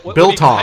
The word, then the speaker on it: biltong: He has been talking about doing his own Bitlong. – Biltong. – How do you say it? – Biltong. – Biltong? Is that what you said? Yes.